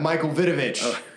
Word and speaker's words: Michael Vitovich: Okay, and today we have Michael Vitovich.